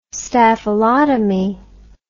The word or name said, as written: staphylotomy